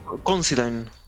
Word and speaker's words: Considine: después continúa Paddy Constantine, se llama. Considine. ¿Cómo se pronuncia? Paddy. Creo que es eh, Considine. – Considine. Paddy Considine.